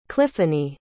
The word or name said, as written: cliffanie